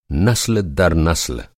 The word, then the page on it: नस्ल-दर-नस्ल: نسل در نسل; Hindi